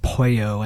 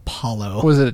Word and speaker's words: pollo: Which I had to remind myself that it's pronounced pollo and not polo.